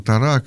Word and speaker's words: Тора: Можно, кстати, говорить Тора, можно Тора, как вам больше нравится, потому что Тора – это на иврите, там ударение на последний слог, а Тора – это по-русски уже сложилось так, поэтому и так правильно.